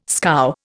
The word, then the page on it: scow: Indian